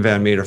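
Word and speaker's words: Van Metre: It's M-E-T-R-E. You can find me on YouTube under Stephen Van Meter Financial, on Twitter at Meter Stephen, again, M-E-T-R-E Stephen, Facebook, LinkedIn, or just put my name in a search engine and you'll find me.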